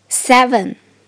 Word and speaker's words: seven: 注意第二个字母 e 发 a，follow me a a seven seven 数字八 eight，其中字母组合 g h 不发音，字母组合 e i 发的音是 a。